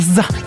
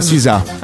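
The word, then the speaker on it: SZA: Si chiama What Lovers Do, Maroon 5 e Zza. – No, non si pronuncia così. – Za. – Si pronuncia Sisa.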